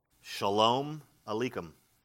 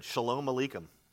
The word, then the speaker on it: Shalom Aleichem: In Hebrew, it is pronounced Shalom Aleichem. Can you say it? Yeah, very good. Shalom Aleichem.